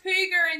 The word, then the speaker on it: hygge: It's actually pronounced hooger in Danish.